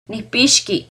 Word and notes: Nicpixqui: Náhuatl

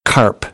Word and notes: carp: American English; ɑːr

